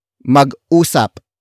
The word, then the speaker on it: mag-usap: Word for word it's mag-usap muna tayo mag-usap muna tayo mag-usap muna tayo The first word is mag-usap